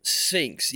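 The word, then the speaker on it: Spinx: Spinks like the big dragon thing or whatever. Yikes. Or not dragon, like the big cat. Like Sphinx. – You know, Sphinx, yeah. But it's spelled Spinks, but they use the – anyways – the QT moved this direction from Atlanta and started to like invade their territory.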